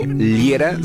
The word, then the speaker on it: Liera: Mi nombre, Fernando Liera, se escribe L-I-E-R-A.